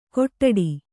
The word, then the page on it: ಕೊಟ್ಟಡಿ: Kannada